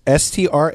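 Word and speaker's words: Strängnäs: Okay, so what happened was at the Strangnäs Cathedral. – Did you say that correctly? – It's Strangnäs.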